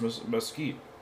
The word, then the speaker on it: mesquite: I would buy a candle that smells like Lay's Mystique barbecue seasoning. – Well, right there. You mispronounced mesquite. – What did I say? – You said mystique.